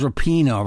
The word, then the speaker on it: Rapinoe: And by the way, we've been saying the woman's name wrong. Her name is Rapinoe, right? Not Rapinoe. – Rapinoe, yeah, you're right.